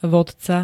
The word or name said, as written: vodca